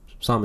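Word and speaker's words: Sahm: Somm is how you pronounce his name, right? – Yeah.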